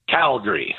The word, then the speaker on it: Calgary: So in Calgary, it is just pronounced Calgary. – Okay. – So C-A-L-G-R-E-E, Calgary.